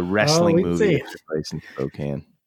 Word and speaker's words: Spokane: Well, I want to give you kudos because you at least pronounce Spokane correctly for that song.